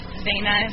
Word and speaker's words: Zanah: Some believe that the Hebrew word, I think it's in our, you know, language, our words pronounced Zanah, Z-A-N-A-H, and they believe it's slightly ambiguous and not used the same each way in the Old Testament.